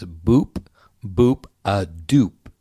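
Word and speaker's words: boop-boop-a-doop: It's boop, B-O-O-P, B-O-O-P, boop, boop. – D-O-O-P. So it's boop, boop, a doop.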